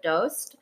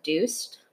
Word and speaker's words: Doost: My dad's from Afghanistan. They pronounce it Doost. – Okay. – My mom's from Iran. They pronounce it Doost. But okay. Like anywhere you go, it's just kind of, it's either Doost or Doost.